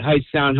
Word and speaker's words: Hightstown: So I went to high school at Heights Town High School. Mm-hmm. It's in Mercer County, so it's spelled H-I-G-H-T-S-T-O-W-N.